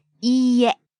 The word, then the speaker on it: いいえ: And be careful when you say いいえ. It has a long e sound. – It's spelled ee, but it's pronounced e. It's a long vowel. – Right. いいえ.